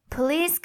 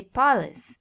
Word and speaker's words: police: Police could have made that list. I've heard many people say police, and by now you've heard me say it police several times, so that's it.